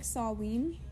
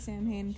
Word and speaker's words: Samhain: It's pronounced, like, Saween, but I say Samhain because that's just, like, the English pronunciation, or how I pronounce it.